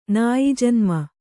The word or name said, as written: ನಾಯಿಜನ್ಮ